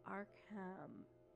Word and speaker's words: Arkham: Arcane, but it's Ark, it's a Hamlet, so Arkham.